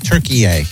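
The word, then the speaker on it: Türkiye: Turkey-ay. That's how we now have to pronounce it. I've learned that in the World Cup. – What do you mean? – Turkey. It's Turkey-ay. – Who says? – Says Turkey-ay. Says pronounce it Turkey-ay.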